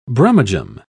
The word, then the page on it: brummagem: British English